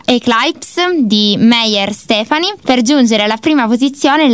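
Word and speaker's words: Stephenie: Salendo alla quarta posizione troviamo Breaking Down di Meyer Steffi. – Stefani, ti correggo. – Stefani, correggiamo, okay, la pronuncia.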